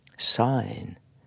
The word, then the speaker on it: syne: Men her må vi holde fast på uttalen. For når en skotte sier sain, så kan han godt i skrift framstille det som syne, for det vil jo da kunne uttales sain.